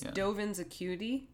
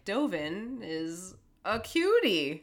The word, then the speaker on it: Dovin's Acuity: It's Dovin's Acuity, but it sounded, when you said it, like Dovin is a cutie.